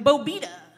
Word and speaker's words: Bobeda: Bobeda.